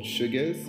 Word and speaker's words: Shugez: It's called Sugars. It's spelled S-H-U-G-E-Z Express.